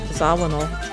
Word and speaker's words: Zawinul: Did I pronounce that right? – Zawinul. – Zawinul.